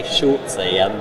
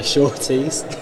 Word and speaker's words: Shorties: Some people say Shortsy. – Shortsy. – But I've got to emphasize that it is the Shorties.